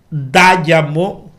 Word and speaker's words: Dayamo: No se trata de eso, sino que hubo un regidor de Jarabacoa, miembro del Comité Central del PLD, Dayamo, así con acento en la A, Dayamo Victoriano Ramírez.